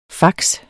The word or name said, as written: fax